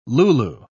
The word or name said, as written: Lulu